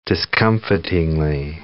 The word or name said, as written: discomfitingly